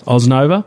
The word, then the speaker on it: Osnova: Now, I think it's probably time we have a little bit of a chat about Osnova. Is that how you pronounce it? – Yeah, Osnova. – Osnova.